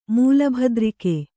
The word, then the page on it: ಮೂಲಭದ್ರಿಕೆ: Kannada